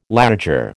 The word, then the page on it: laager: American English